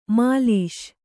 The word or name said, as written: ಮೆಲಿಸು